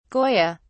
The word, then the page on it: goya: British English